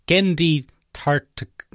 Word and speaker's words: Gennady Tartakovsky: You got most of the syllables right, Ben. It's Gennady Tartakovsky.